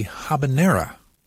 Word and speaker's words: habanero: On occasion, one of those is the habanera. The correct pronunciation does not make use of the Y sound.